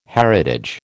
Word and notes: heritage: American English

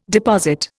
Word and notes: deposit: Indian

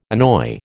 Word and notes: annoy: American English

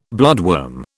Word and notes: bloodworm: British English

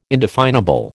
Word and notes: indefinable: American English

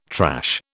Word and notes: trash: British English

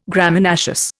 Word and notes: Graminaceous: Indian

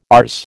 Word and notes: ours: American English

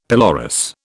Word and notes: pelorus: British English

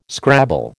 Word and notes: scrabble: American English

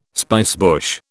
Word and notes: spicebush: British English

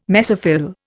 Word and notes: mesophyll: Indian

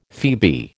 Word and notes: phoebe: American English

